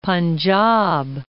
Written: Panjab

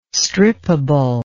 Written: strippable

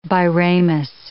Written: biramous

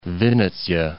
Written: Vinnitsa

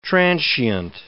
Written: transient

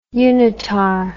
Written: unitar

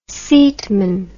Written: seatman

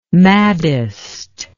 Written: maddest

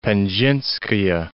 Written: Penzhinskaya

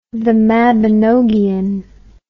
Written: mabinogion the

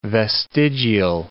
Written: vestigial

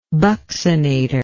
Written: buccinatory

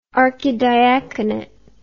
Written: archidiaconate

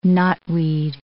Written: knotweed

